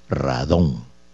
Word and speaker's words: radón: Radón, RADDON, acento en la O, radón.